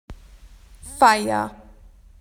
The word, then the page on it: party: Australian English